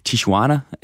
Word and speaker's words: Tijuana: Og derfor så skal de bo i den mexicanske grænseby Tijuana, hvis udtalen er simpelthen korrekt. – Det er den.